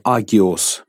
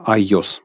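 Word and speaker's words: айос (ἅγιος): Сразу хочу сказать, что слово «агиос» по-гречески — это «святой». Но произносится «айос» по-гречески. Это почти не произносится вслух, но «агиос», «агиос».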